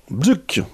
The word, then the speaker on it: BCUC: Nous écoutions Bzuk, un groupe d'Afrique du Sud. Alors ça s'écrit B-C-U-C, avec le morceau Vindé.